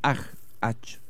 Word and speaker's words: Hajj: el Hajj, se pronuncia así.